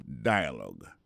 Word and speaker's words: dialogue: Dialogue. L'écrit exactement même genre en français, D-I-A-L-O-G-U-E, mais américain ont prononcé dialogue.